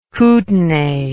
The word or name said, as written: Kutenai